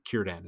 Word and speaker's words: Círdan: The C's in Sylvan languages, or rather Elven languages, are generally hard C's. So Celebrimbor is a hard C, Círdan is a hard C, and in most cases it's going to be hard C.